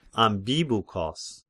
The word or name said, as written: Ambibuchos